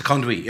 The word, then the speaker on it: conduit: That's how it's pronounced. – Conduit. Yeah, the word. – You weren't just saying it for a laugh. – No, it's conduit. Okay.